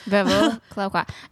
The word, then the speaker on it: Veuve Clicquot: And if you're wondering how we know how to say this properly, we learned when we were at Disney's, was it the Food and Wine Festival? – Yep. – They taught us the Veuve Clicquot.